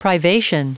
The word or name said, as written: privation